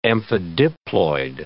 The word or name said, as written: amphidiploid